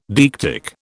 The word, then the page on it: deictic: British English